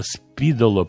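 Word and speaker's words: Spīdola: Я вам признаюсь, что у меня в 64 году, в 64-м я еще был подростком совсем, появился приемник спидола. На самом деле ударение правильно Спидоло, по-моему, э, по-латышски, но неважно, называли его Спидола, стоил он серьезных денег, около 100 рублей, 95, по-моему, об этом тоже стоит поговорить, вспомнить.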